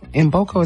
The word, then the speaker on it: Mboko: I want to say Mboko. Mboko is how you say it. Victoria Mboko is M, is K-M-B-O-K-O.